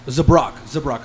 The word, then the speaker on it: Zabrak: It pronounced Zabarak or Zabarak? – Zabrak.